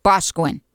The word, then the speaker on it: Boscawen: Is it Boscawen or Boscawen? – Boscawen. – There we go. Got to say it right.